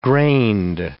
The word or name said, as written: grained